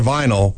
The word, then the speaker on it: vinyl: Remember, for fencing, DLR vinyl rhymes with final.